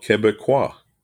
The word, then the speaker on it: Québécois: The 2 would be called Québécois.